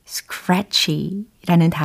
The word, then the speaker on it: scratchy: 가려운, 따끔거리는 이라는 의미의 scratchy라는 단어예요. scratchy. 네, 끝부분에 chi라고 발음이 되었어요. 철자는요. s-c-r-a-t-c-h-y.